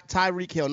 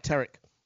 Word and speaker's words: Tyreek Hill: And Tyreek Hill, not Tarek. – Well, I apologize, man. That was the African in me. I pronounced it wrong.